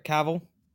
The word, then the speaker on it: Cavill: Cavill? I'm sorry. – No, you said it right, Cavill.